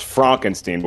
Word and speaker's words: Frankenstein: It's actually pronounced Frankenstein, but we'll accept it.